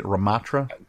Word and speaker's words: Ramattra: It's a new tank hero named Ramattra. Is that how you say it? Ramattra? – Sure.